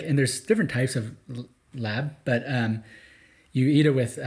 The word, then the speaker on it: larb: Is it just called Lab? It's pronounced like that, but it looks like L-A-R-B.